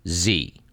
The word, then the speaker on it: Xe: written out, XE, pronounced Z. Blackwater is now Z.